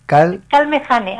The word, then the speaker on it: Calmejane: Calmejane, así como se escribe.